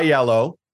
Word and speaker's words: iello: So the the rumor is uh, I yellow or I, it could be pronounced yellow, uh, but it's spelled I E L L O.